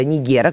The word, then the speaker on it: Нигер: Французские войска покинули базу на западе Нигера, Нигера, кому как больше нравится ударение, мне нравится по классике советской, Нигер.